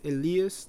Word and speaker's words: Eli: My name is Elias, that's how you pronounce it. I know for the Americans only speaking people, it's kind of hard to pronounce it. You can call me Elias if it's easier, or Eli.